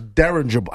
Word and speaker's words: dirigible: And then I'm thinking, I thought it was something with a D, and it was dirigible. How do you say dirigible? – Dirigible, yeah. I think is how you pronounce it. – That's a tough word right there. – D-I-R-I-G-I-B-L-E.